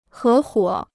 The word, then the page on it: 合伙: hé huǒ